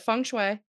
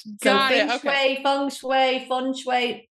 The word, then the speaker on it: Feng Shui: First off, I want to ask, how do you pronounce, is it Feng Shui? – That's perfectly pronounced. I don't care how you pronounce it, to be honest. I just want you to, like, implementing it. – Feng Shui.